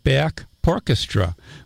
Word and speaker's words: Back Porchestra: And why don't we, we won't do the ticket giveaway, because right now I see we finally found Back Porchestra, which, that's how it's pronounced, P. And then orchestra, so just another one making a hard time with the consonants there for us disc jockeys.